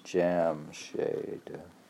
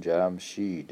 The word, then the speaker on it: Jamshid: Jamshade, pronounced Jamshade. In Dari, Telek, Taljik, and Urdu, and Jamshid in Persian, Iranian Persian.